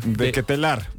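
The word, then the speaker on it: De Ketelaere: ¿cómo se le pronuncia a él? – De Ketelar. Sí. Pero muy bien, Sebastián.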